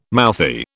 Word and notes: mouthy: British English

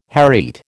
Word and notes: hereat: American English

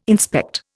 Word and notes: inspect: Indian

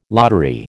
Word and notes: lottery: American English

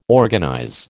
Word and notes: organize: American English

